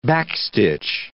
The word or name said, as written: backstitch